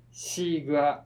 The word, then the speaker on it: 西瓜: これちなみにスイカってなんでスイカっていう語源とかあるんですか、まあ、当時の中国語がでこの西にウリって書いて、シーグアって読むんですけど、